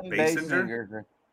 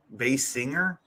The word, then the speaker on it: Basinger: Kim Basinger. That's how you say it. – Bass singer? – Sort of. Bass singer.